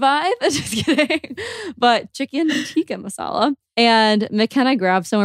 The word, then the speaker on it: tikka: Am I saying that right? Chicken tikka masala? Tikka masala? I think it's tikka. – Yeah. – I always say chicken tiki and I know that's wrong every single time.